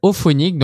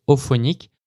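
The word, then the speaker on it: Auphonic: Et en audio, on va utiliser un outil qui s'appelle Ophonic. Donc, tu peux faire une recherche Google, tu tapes formater un fichier audio pour un podcast avec Ophonic. Ophonic s'écrit A-U-P-H-O NIC, Donc, tu vas tomber sur un tutoriel à moi.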